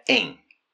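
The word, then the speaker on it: en (円): Como todos sabemos, la capital de Japón es Tokio y la moneda nacional es el yen, que en japonés se pronuncia en.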